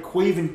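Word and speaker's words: Caoimhín: Like, Liverpool have got a player called Cuivin Kelleher. Cuivin Kelleher, his name is spelled C-A-I-M-O-N.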